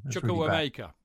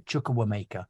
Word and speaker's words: Chukwuemeka: But might he not bring on Chuchuweya or whatever his name is? I've forgotten. Sorry, forgive me the pronunciation. Chukwuemeka. Sorry, Chukwuemeka.